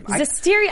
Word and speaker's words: Zestiria: I think one of the ones you mentioned on Sunday was Tales of Zestiria? – Zestiria. – I don't know how to pronounce it. – I don't think I can either. No, it's Zestiria.